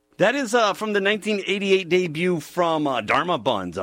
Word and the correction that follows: Dharma Bums: Bums. Bums, I think I said it right. Not Buns, Bums.